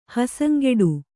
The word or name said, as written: ಹಸಂಗೆಡು